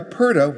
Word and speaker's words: purdah: It's spelled P-U-R-D-A-H. It's pronounced purda.